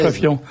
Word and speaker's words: François Fillon: Il faut faire attention, par exemple, à bien prononcer François Fillon, à respecter la diérèse. – François Fillon.